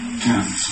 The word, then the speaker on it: Thames: How do you pronounce the river here? Thames, okay.